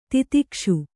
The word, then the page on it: ತಿತಿಕ್ಷೆ: titikṣe